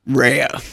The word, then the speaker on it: Rea: Three letters and two syllables. It's confusing to a lot of people. – Because it can easily be just read as Ray. – And it was Americanized to being Ray. So I'm doing sort of a version of an obnoxious third generation, well, second generation Italian American thing, which is I am reclaiming the original pronunciation where,